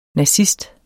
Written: nazist

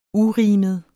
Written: urimet

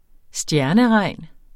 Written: stjerneregn

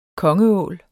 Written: kongeål

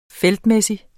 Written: feltmæssig

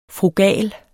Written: frugal

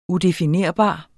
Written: udefinerbar

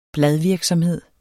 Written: BLADVIRKSOMHED

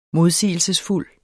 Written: modsigelsesfuld

